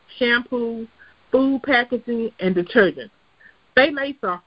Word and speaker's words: phthalates: The next chemical we're going to talk about is called phthalates, and it's spelled really funny. It's phthalates with T-H-A, but it's spelled with a P, P-H-T-H-A-L-A-T-E-S. Phthalates.